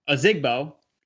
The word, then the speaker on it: Ozigbo: If it was up to me, I still prefer Divino Ozigbo. That's how you pronounce his name. Divino Ozigbo.